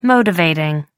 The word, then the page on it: motivating: American English